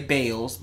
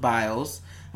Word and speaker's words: Biles: That's how you say it. I was going to say Bales, but Biles.